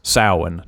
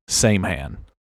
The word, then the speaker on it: Samhain: That's pronounced Samhain, not Samhain. The short answer is no.